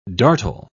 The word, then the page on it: dartle: British English